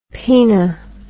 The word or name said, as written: poena